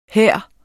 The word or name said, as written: her